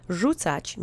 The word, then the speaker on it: Rzucać: Rzucać means to throw. It's the infinitive of the verb. The accent is on the first syllable.